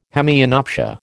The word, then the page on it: hemianopsia: American English